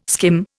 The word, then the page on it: skim: Indian